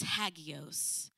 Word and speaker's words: hagios: That word holy, I'm going to butcher it, is hagios. Hagios, H-A-G-I-O-S. It's a Greek word.